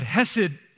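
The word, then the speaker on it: Hesed (חסד): But hesed is a word that can be translated loyal love, loving faithfulness, or grace. And you have to say it like you have a furball stuck in your throat.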